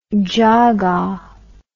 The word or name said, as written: jaga